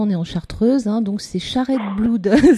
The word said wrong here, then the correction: Shared Blood: c'est ça ? – Voilà, Charette, alors je vais rectifier, ça dit Shared Blood, Shared D'accord.